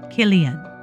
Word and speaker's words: Cillian: And the first name of the Irish actor Cillian Murphy is pronounced Cillian.